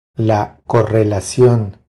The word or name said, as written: La correlacion